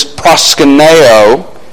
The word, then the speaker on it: proskuneo: And to pronounce them in English from their original word in the Greek, one word is proskuneo.